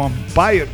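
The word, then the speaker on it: Vampyr: Empezamos con Vampire o Vampire, porque se escribe V-A-M-M-P-Y-R. Así que no es Vampire de Vampire, como se escribe en inglés, ¿sí? Es como se escribe Vampiro en alemán.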